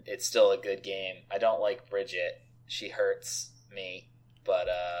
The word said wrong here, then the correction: Brigitte: it's Brigitte. That's how you pronounce it.